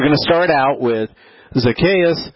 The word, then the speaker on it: Zacchaeus: And so, it's actually Zacchaeus is how you say his name. But I've always heard it just Zacchaeus.